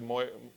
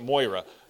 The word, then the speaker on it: Moria: We live off of Moira, and so I pronounce it Moira. Okay, it's the same letters. It's just arranged differently. Okay, and Frodo says to Gandalf, he says, "I wish the ring had never come to me.